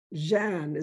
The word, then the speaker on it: Jeanne: I have a personal dream because my name is Jeanne, is how you really pronounce it in French, J-E-A-N-N-E.